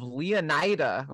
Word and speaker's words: Leonida: The game takes place in the states of Leonida, or however we pronounce that. – Ooh. – It's Leon with an IDA attached.